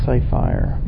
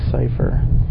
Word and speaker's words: Ciphire: That's C-I-P-H-I-R-E. A lot of people pronounce it Cyphire, but I believe it's Cipher.